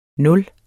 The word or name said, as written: nuller